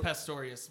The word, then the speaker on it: Pastorius: It is Pastorius.